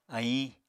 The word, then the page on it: aiñ: Apatani